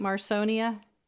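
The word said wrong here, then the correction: Marssonina: Marsonina or Marsonina. – Marsonina, okay.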